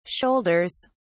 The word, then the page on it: shoulders: British English